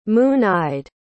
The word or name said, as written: moon-eyed